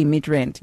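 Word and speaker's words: Midrand: Mid-rent.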